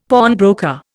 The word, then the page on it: pawnbroker: Indian